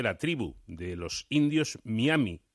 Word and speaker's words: Miami: deberemos decir eh, Miami o Miami. Pues esto a mí me consta que produce sonrojo a muchos, pero eh, la forma tradicional de pronunciarlo y la consecuente con el modo en el que está escrito es Miami. En español lo que se recomienda es eh, decir y pronunciar Miami. Cuestión distinta es que en inglés sea Miami, pero eh, en, español no.